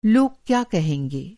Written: कहना

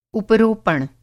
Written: उपरोपण

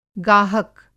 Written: गाहक

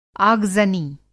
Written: आगज़नी

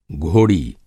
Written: घोड़ी

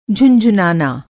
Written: झुनझुनाना

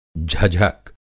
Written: झझक

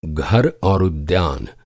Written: उद्यान

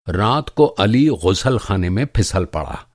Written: ग़ुस्लख़ाना

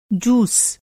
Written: जूस

